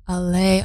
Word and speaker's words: Allez: And producer Roland Verloven in a song called Underlocked Champion, originally sung in French as Allez, Allez. That makes sense, actually. I'm reading this for the first time, so I'm learning it while I'm telling you this. And it's spelled A-L-L-E-Z, but Allez. Allez, Allez.